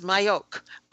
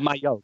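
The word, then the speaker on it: Majok: No, it is Mayhoek. I asked. – Oh, Mayhoek. Oh, I'm sorry. – Yeah, she actually said. Yeah. She said it's like Mayhoek, like in the tree. Martina Mayhoek.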